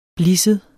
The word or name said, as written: blisset